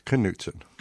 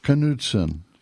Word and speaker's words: Knudsen: He pronounces the K. It's Knudsen. – Knudsen, yes. Well, it sounds more Norwegian.